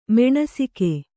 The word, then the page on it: ಮಾನಸಿಕ: mānasika